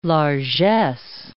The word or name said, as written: largess